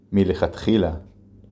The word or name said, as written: מלכתחילה